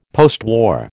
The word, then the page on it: postwar: American English